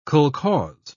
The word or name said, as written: kolkhoz